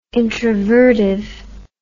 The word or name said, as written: introvertive